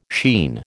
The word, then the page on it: sheen: American English